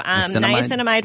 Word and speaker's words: niacinamide: Did I say that right? – Yeah, so niacinamide,